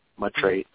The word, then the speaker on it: Maitreya: Is it Amitreya or Maitreya? – Yeah, he says, let me just ask him real quick, or ask Maitreya. How do you say your name? Maitreya.